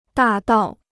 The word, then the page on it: 大道: dà dào